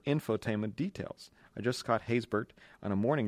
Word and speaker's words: Haysbert: Haysbert.